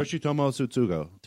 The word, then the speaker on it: Yoshitomo Tsutsugo: Yoshitomo Tsutsugo.